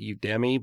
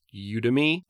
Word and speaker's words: Udemy: I used to call it Udemy, but it's pronounced Udemy.